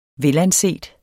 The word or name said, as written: velanset